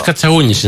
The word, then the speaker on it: Κατσαούνης: Και ζητώ συγγνώμη αν δεν προφέρω σωστά το επίθετο. – Κύριος Κατσαούνης είναι όντως, ναι.